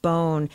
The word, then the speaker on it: Borgen: I don't know about you, but the Danish political drama spelled B-O-R-G-E-N, but pronounced bone, over the past few months has been popping up in my Twitter feed.